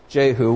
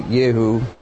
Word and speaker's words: Jehu: When Yoram saw Jehu, which would be Yehu, he asked, have you come in peace, Jehu?